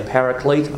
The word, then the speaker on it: paraclete: P-A-R-A-C-L-E-T-E. Some people say paraclete. I tend to say paraclete because I think it's a little closer to the pronunciation of the original Greek word, which is parakletos.